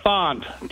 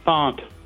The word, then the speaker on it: Thant: It's Font. T-H-A-N-T. Font. – Got it. P-H-A-N-T.